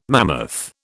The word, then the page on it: mammoth: British English